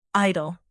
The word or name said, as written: idle